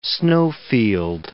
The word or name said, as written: snowfield